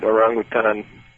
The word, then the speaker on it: orangutan: I pronounce it orangutan because I've lived in Indonesia a lot, and that's the Indonesian pronunciation, and it's an Indonesian Malay word.